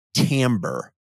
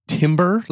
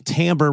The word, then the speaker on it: timbre: do you know what the word timbre refers to in a musical context? – Wait, isn't that the word that is often mispronounced as timber, like timber? – Yes, it is. Timber is spelled T-I-M-B-E-R, whereas timbre is spelled T-I-M-B-R-E.